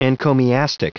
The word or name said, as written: encomiastic